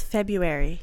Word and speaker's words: February: February. You know the word is February, right? You know it. That's how you say it.